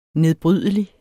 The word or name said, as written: nedbrydelig